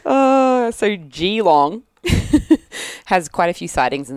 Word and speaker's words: Geelong: Pronounced Geelong.